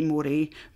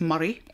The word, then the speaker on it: Moray: Is it A, Angus, B, I better say this right, I would say Moray, but it's Murray.